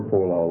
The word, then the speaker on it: polal: Yeah, no, polel or polal. The only difference is the last syllable. Polel, el, or polal, al. See the page 254 there? Polel or polal.